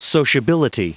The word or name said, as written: sociability